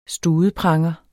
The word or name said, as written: studepranger